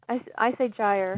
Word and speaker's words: gyre: And as I pointed out, I had do you pronounce Pacific gyre or gyre? – I say gyre.